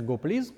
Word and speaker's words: gopls: Произносится он и читается GoPlease.